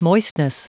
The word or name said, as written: moistness